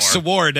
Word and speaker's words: sword: Like Sword and Pharaoh. – Right. Oh, yeah. – Pharaoh. – It was really funny to pronounce the W in Sword